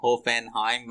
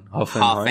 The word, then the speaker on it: هوفنهایم: هوفنهایم هوفنهایم نه هوفنهایم آقا هوفنهایم من اینجا تلفظم متاسفانه تحت تاثیر رسانه های شرق و غرب تلفظ خالص آلمانیم ضعیفه